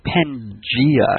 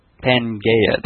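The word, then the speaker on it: Pangaea: Tell him what it's pronounced. Pangea. – Is it really pronounced Pangea? Because that's what I thought it was. – Pangea. Yes. – All right. – Not Pangea. That would be the all gayness.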